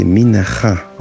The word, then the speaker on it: Minha: Mina. Alors premièrement, faut savoir que la réelle prononciation du mot mina, c'est minacha en fait.